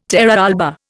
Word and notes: terra alba: Indian

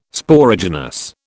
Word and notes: Sporogenous: British English

